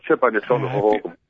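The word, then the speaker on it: Hohokam: Is it Ho-Ho-Cam or Ha-Ha-Cam? – Ho-Ho-Cam. Okay, yeah, exactly, Coach. You got it.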